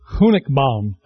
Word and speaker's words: Honigbaum: Say it. Yes. Honigbaum.